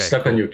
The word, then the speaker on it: Stepanyuk: Is, is that correct? Did I did I did I not butcher it? – Perfect. Step, okay. Stepanyuk. Cool.